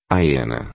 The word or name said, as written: hiena